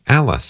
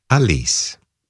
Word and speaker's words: Alice: Coming up in just a moment, a pair of waltzes by Frédéric Chopin, performed by the pianist Alice Sarah Ott, who I have for years been mispronouncing. I was pronouncing it the, you know, American way, Alice Sarah Ott. She's from Germany, where it is Alice.